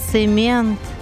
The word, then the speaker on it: цемент: Запомнил я один момент, когда к нам завезли цемент.